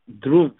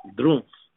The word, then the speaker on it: Drumpf: The original name of the Trump family was actually the German name, Drumpf. It's spelled D-R-U-M, like in Mary, P like in Peter, F like in Frank, Drumpf.